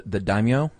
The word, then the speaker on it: daimyo: No, they serve their master, the daimyo. Is that how you pronounce it? – I believe so.